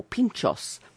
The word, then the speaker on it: pintxos: But the other word for that is pinchos, or pinchos, which is spelled P-I-N-T-X-O-S, and that's more served in the northern part of Spain, around Bilbao and up that end.